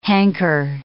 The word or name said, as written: hanker